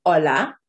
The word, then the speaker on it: hola: If you have done the phonics lesson, you will know that the H is silent at the beginning of the word hola. H is always silent at the beginning of Spanish words.